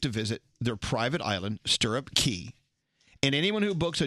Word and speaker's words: Stirrup Cay: If you take a cruise to the Bahamas, you know, they have their own private island, Stirrup Cay or Stirrup Key. – Stirrup K. – Well, it's spelled C-A-Y, but they usually say key.